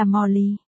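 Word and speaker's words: Molly (茉莉): Molly không phải tên tiếng Anh của Hoa Nhài, chỉ phát âm là Hoa Nhài, đây cũng là tên tiếng Anh Cảnh Lị đăng ký trên hộ chiếu. Hoa Nhài có phát âm là Molly.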